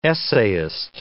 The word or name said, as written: essayist